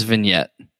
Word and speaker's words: vignette: So that sounds vignette.